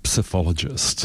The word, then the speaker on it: psephologist: Our own community's resident psephologist.